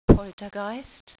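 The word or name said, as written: Poltergeist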